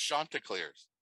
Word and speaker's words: Chanticleers: Pronounced Chanticleers. – Chanticleers. Okay. – Because I asked somebody and I got yelled at online.